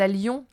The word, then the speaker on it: allions: Que j'aille, que tu ailles, qu'il, elle, aille, que nous allions. Attention, il y a deux L, mais on prononce comme s'il n'y en avait qu'un seul L.